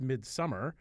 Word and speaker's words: Midsommar: It's, I believe, pronounced Midsommar, but it's spelled S-O-M-M-E-R, Midsommar.